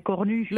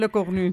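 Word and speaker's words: Lecornu: Η προφορά του ονόματος του πρωθυπουργού είναι Le Cornu. – Le Cornu.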